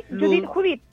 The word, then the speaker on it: Judith: Judith. Se escribe tal como suena, ¿cierto? – Sí, Judith.